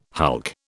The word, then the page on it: hulk: British English